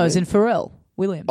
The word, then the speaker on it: Farrell: Perry Farrell from Jane's Addiction. That's how you pronounce his name.